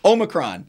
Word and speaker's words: Omicron: Omicron. Did I say it right? – Omicron.